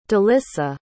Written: delissa

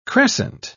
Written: crescent

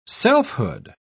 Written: selfhood

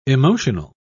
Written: emotional

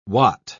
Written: wat

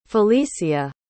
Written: falicia

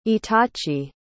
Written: itachi

